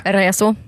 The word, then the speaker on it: Raisio: Är det Raisio? – Ja. – Just det. Okay. Vad sa du? Hur ska man uttala det? – Reso.